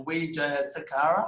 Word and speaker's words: Wijayasekera: So, we've got, from Temperzone, we have Ryan Wijayasekera.